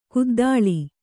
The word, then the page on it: ಕುದ್ದಲ: kuddala